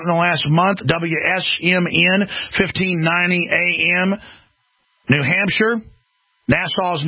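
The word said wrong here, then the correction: Nashua: News and Talk. Am I pronouncing that right? Nashuas.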